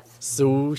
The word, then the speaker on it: sous: 那是美式的说法，不是吗？真正 gay 搞的说法是什么？不是 sous chef 吗？Yeah, sous chef. – 对，不是 sous chef 哦，是 sous chef. – Sous chef.